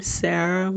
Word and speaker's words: Sarah: Um, so that's my name, Sarah with an H um, underscore and the word journalist.